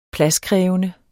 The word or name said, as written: pladskrævende